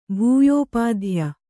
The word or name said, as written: ಭೂಯೋಪಾಧ್ಯ